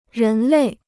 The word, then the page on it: 人类: rén lèi